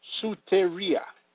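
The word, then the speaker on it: soteria (σωτηρία): The Greek word for salvation in the above text of Scripture is pronounced soteria, meaning rescue or safety, to deliver, health, salvation, saving.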